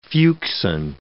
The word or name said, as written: fuchsine